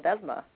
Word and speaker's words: Ledesma: Ledesma.